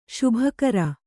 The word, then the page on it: ಶುಭಕರ: śubha kara